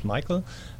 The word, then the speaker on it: Michael: I remember that part, but you said at home in Germany you pronounce it another way, right? – And that's true. That's true. – Okay. – Michael is fine.